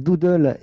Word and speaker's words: Doodle: Qu'est-ce que c'est alors, euh, alors, moi je le prononce Doodle, mais je me, trompe peut-être. Hein. c'est Doodle et Meet euh, donc D-O-O-D-L-E et, ben Meet Me.